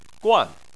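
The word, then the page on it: guan4: tone 4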